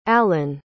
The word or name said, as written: allyn